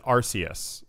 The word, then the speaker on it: Arceus: So this is Pokemon, and you pronounce it Arceus. – That's correct. – Okay, so Pokemon Arceus. – Or Arceus.